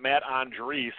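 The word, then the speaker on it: Matt Andriese: But they sent Matt Andrees, is apparently how it's pronounced. I've been pronouncing it wrong all these years.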